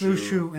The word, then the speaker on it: Luxu: It's pronounced Lushu in the games, so that's how I'm gonna pronounce it. Get over it. – It is Lushu. It's a Latin.